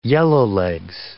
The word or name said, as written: yellowlegs